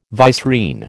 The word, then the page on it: vicereine: American English